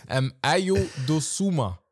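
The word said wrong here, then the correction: Dosunmu: oder Dosumu. Dosumu.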